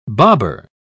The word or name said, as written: bobber